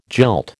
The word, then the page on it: jilt: American English